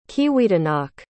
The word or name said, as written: Kiwidinok